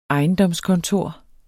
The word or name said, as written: ejendomskontor